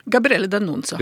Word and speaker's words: Gabriele D'Annunzio: Jeg nevnte denne Altså Gabrielle Danuncio. Hvordan uttaler jeg det? – Gabrielle Danuncio. – Gabrielle, helt ja. riktig.